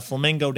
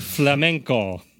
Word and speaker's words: flamenco: When these guys were touring in Venezuela, they came across a flamenco dancer. – I think you mean Venezuela and flamenco.